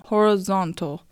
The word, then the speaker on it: horizontal: 水平線は horizon ですが、こちらは RI が ri と発音されますが、水平という意味の horizontal は RI の部分は ra という発音になります。